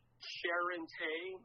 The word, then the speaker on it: Charente: Yes, it, that's the French Charente melon.